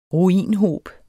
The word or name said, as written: ruinhob